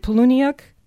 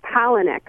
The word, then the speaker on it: Palahniuk: Am I saying this right? Is it Poloniuk? – It's Palahniuk. – Palahniuk.